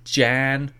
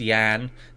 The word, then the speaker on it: Jan: Iris, Jan. Sorry, it's pronounced Yan. They actually didn't make that clear. They put Yan underneath it, so the J is a hard J, soft J.